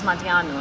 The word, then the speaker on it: Madianou: But my surname is usually anglicized as Madianou, and I'm happy with that.